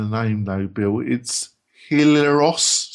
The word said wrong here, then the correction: Helios: Solutions Social Media Buttons. Right? – Make sure I can find that. Say again. – Helios. Helios.